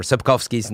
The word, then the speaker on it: Sapkowski: Andre or whatever Sapkowski's name is. – Yeah. Oh, nice. That's good pronunciation. – I try. W's or V's sometimes, I think, over there.